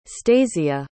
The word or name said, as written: stasia